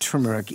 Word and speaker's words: turmeric: I don't call it turmeric anymore. I call it turmeric. Even my daughter yelled at me and she said, blah, blah, blah, blah, blah. And there were some swear words in there. Why don't you get it right? It's turmeric, Dad.